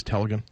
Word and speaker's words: Telegin: You know, you see a little bit of a burst from a guy like Telegin, however you pronounce it. – Yep. I think it's Telegin.